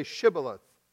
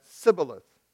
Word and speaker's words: Shibboleth: Then they would say to him, "Now say Shibboleth." But he said, "Sibboleth," for he could not pronounce it correctly.